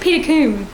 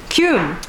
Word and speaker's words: Peter Combe: I think it's pronounced Peter Combe. – Combe.